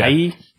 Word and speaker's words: Nighy: His last name is spelled N-I-G-H-Y. Yeah. Nighy? Yeah. – It's still pronounced Nighy.